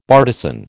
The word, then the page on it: bartizan: American English